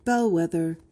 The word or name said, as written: bellwether